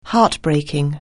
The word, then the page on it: heartbreaking: anglais